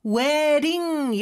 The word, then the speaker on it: wedding: wedding 이렇게 발음이 되는데 d 발음은 모음 소리 사이에 있어서 ㄹ처럼 발음이 돼요. 그래서 wedding, wedding 이렇게 발음이 되죠.